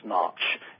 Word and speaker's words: Schnarch: It's 18 or so years ago now, I got into counseling for 5 years with Dr. David Snarch. It's spelled S-C-H-N-A-R-C-H.